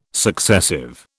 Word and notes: successive: British English

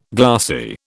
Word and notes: glassy: British English

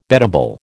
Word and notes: beddable: American English

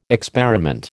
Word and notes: experiment: American English

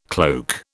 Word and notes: cloak: British English

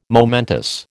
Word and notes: momentous: American English

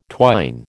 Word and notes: twine: American English

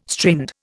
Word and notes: strained: Indian

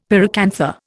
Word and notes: pyracantha: Indian

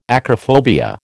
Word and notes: acrophobia: American English

